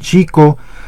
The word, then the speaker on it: Xico: También hablando de lo que viene por delante, el próximo miércoles en la Plaza de Toros Alberto Valderas en Chico, allá en Veracruz, en Xico, ya como también nos hacemos bolas con la pronunciación de la X, en Xico,